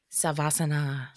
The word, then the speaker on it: Savasana: Savasana.